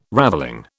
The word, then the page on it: ravelling: British English